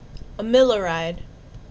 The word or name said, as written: Amiloride